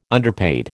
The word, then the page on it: underpaid: American English